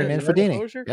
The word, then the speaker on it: Manfredini: We got it signed by Mrs. Voorhees, Betsy Palmer, rest in peace, Kane Hodder, Steve Dash, Henry Manfredini. Did I say that right? Right. – Henry Manfredini.